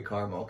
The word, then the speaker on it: Carmel: Is it caramel or Carmel? – It's definitely Carmel, because there's not another A in it. It's C-A-R-M-E-L.